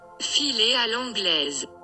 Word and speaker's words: filer à l'anglaise: French pronunciation is not great, so let's have Google tell us. – Filer à l'anglaise. Filer à l'anglaise. – Filer à l'anglaise. There you go.